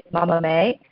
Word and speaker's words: Mama Mae: And the way you pronounce it is Mama Mae. It's a combination of the word mama, which means mother, and mame, which means beans in Japanese.